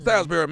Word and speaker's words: Salisbury: Salisbury. Mm-hmm. Salisbury, Maryland.